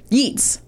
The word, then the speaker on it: Yeats: Yeats.